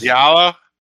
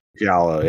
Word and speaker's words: Giallo: But we're going to watch 1973's Giallo. – Giallo, yeah.